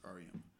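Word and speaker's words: Auriemma: And Ariema, that's his last name. That's how you pronounce it, Ariema.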